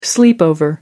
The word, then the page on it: sleepover: American English